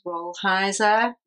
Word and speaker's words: Rolheiser: Um, it's a book called Domestic Monastery um, by Ronald Rolheiser.